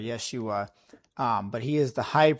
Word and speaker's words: Yeshua: Joshua, or as it's other, like in other parts of the Bible, it's Yeshua, or it's pronounced Yeshua, which would actually be the Hebrew form of Jesus we have in the New Testament.